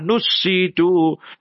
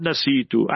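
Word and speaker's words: نُسِّيتُ: اللَّهُمَّ ذَكِّرْنَا minhuma nusitu, not nasitu.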